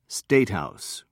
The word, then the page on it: statehouse: American English